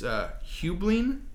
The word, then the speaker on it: Heublein: The company was bought out by a food conglomerate called, and I'm going to butcher it, it's Hublin, H-E-U-B-L-E-I-N.